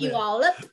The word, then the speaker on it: Puyallup: I could say Puyallup. That's about Puyallup.